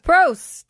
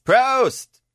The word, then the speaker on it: Prost: So, how do you say it? Prost? – Prost. – Prost.